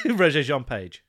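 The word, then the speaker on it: Regé-Jean Page: But Regé Jean-Paul's character. – Regé Jean-Page. – What? – Regé Jean-Page. – I've called him Regé Jean-Paul this entire time, and he's called Regé Jean-Page.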